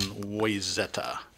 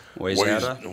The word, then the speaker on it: Wayzata: One's in Owatonna, Shakopee, Plymouth, Bloomington, and Wayzata. That I'm using the out-of-state announcer pronunciation of the Wayzata. Wayzata. Yeah, they say Wayzata all the time.